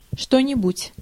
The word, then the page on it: aught: American English